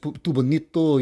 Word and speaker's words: Tubonito: For those who wish to nail this pronunciation against Tubonito, you kind of pull back on that U a little bit against pulling your lips out, and you know, forward and then back, and you know, Tubonito.